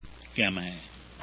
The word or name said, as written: gamay